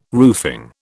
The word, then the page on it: roofing: British English